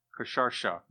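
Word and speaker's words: Khshayarsha: Kasharsha. This is not an easy pronunciation, no matter how you slice it. So the Hebrew wrote Ahasuerus for Kasharsha, and the Greeks wrote Xerxes. Almost sounds like a copier.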